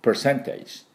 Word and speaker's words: percentage: percentage。で、 p の音、プップップップップ。で、 per、これ、ちょっと r が入りますね。あっていう半母音の音に r が入ります。 per、 per、こんな感じです。 percentage。多分、イギリスではそこまで、いきなり percentage っていうような気がしますが、 r 入れなくて。でアメリカにはしっかり入れていきます。こういうところに、短いところにね。 percentage。